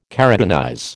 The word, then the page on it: keratinize: American English